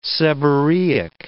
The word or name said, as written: seborrheic